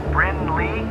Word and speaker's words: Brynleigh: Bryn Leg Pasternak. – I think it's pronounced Bryn Lee.